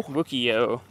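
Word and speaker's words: Wukiyo: Wukio.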